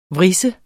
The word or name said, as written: vris